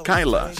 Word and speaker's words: Kyla: 6 semanas no consecutivas en el primer lugar, Drake con Whiskey y Kyla. Siempre se me olvida decir la pronunciación es Kyla. K-Y-L-A. Porque Kyla sonaría como killer. Como dicen otras personas, lo pronuncian Kyla. No, este es Kyla.